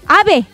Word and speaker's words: AB: Ave. – Ave. – Ven acá, Ave. – Ave. AB. – Oye, ¿sabes qué? Ya se me quedó. Ave Quintanilla, si le voy a decir toda la intervención. Ave.